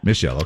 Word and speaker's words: Michelle: Michelle. – Hi, Michelle. How are you? – Good. How are you guys? – Good. Did I say it right, Michelle or Rochelle? – Michelle, okay.